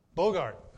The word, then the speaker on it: Boogaard: How do you pronounce this guy's name? Boogaard. Is it Bougard or is it Bougard or? Bogard. Okay.